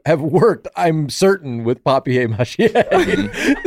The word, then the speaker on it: papier-mâché: It's French. Papier-mâché. It's like a French word. – Yeah. – Papier mâché. – Paper mâché. Sorry, I'll pronounce it your way.